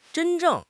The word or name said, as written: zhen1 zheng4